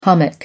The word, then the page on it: hummock: American English